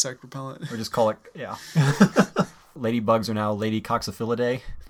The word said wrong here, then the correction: Coccinellidae: Did I say that right? – No. Coccinellidae. – Coccinellidae. Lady Coccinellidae.